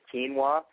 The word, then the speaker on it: quinoa: There is some higher protein, low glycemic grains such as quinoa, which is spelled with a Q, so it's Q-U-I-N-O-A, and they even have quinoa pastas, which you can get at the health food store.